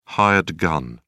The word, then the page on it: hired gun: British English